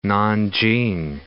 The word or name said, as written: Nanjing